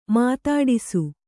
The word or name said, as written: ಮಾತಾಡಿಸು